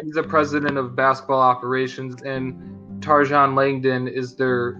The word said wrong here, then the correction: Trajan: You can't butcher the Alaskan assassin's name, sir. The the original J.J. Redick before J.J. Redick. Trajan Langdon.